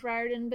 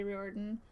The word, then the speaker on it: Riordan: And I know that his name is pronounced Riordan, but it sounds so weird in my mouth that I continue to say Riordan.